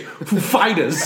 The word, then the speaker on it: Foo Fighters: When Chris Walken was hosting SNL with the Foo Fighters play, he asked the Foo Fighters, what syllable do I stress for Foo Fighters? The first or the second? They were like, definitely the second.